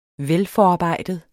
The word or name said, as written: velforarbejdet